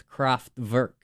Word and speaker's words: Kraftwerk: Do not pronounce their name. It's Kraftwerk. So get it right.